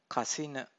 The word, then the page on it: kasina: Pali